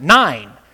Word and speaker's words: Nain: And this is the healing of the widow's son, or the resurrection, of the widow's son from Nain, spelled N-A-I-N. Okay? Now, why don't you pronounce it like that, Pastor? Because that's how you pronounce it. Nine.